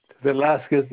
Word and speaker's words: Velazquez: Velasquez is spelled V-E-L-A-Z-Q-U-E-Z, like the famous painter Velasquez.